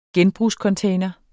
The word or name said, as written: genbrugscontainer